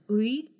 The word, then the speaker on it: ы: The Russian will pronounce it as uy. It's very hard to pronounce it in isolation because that letter never stands alone.